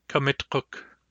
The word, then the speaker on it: Kamitkuk: Kamitkuk. Just as with uukuk and wiwikuk, it'll be a challenge to pronounce the Q and the K properly in this word.